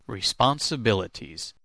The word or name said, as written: responsibilities